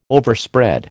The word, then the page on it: Overspread: American English